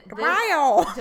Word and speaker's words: Dewayo: D-E-W-A-Y-O. And this... Dewayu!